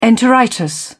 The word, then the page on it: enteritis: British English